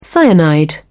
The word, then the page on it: cyanide: British English